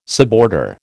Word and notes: suborder: American English

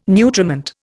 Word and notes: nutriment: Indian

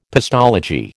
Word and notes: pestology: American English

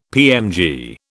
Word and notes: PMG: British English

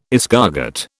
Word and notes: escargot: British English

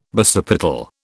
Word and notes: basipetal: British English

